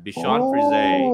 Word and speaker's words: Bichon Frise: R-I-S-E. Yan. Bichon Frise.